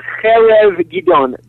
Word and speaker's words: Harav Gidon: It's a Hebrew word. It's a guttural chet. – Okay. – So it's the Harav Gidon.